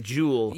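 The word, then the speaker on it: Juel: It's J-U-E-L. So I said Jewel.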